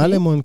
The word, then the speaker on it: אלמון: אלמון? ככה מבטאים את זה? אוקיי. אלמון, כן.